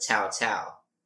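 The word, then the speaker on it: Cao Cao: His name is Tao Tao. Okay. It's like Cow Cow, but Tao Tao.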